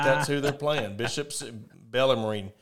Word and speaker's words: Bellarmine: Bellarmine. If that's how you pronounce this, it's – Bellarmine? – Bellarmine. Yeah, Bellarmine.